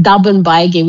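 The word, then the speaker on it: Durban Bagii: Or you can check in my weaving, which is pronounced Dubbin Baigi, which means carrying bags. That's my Yidini language, but it's actually spelled D-U-R-B-A-N and then Baggy, B-A-G-I-I.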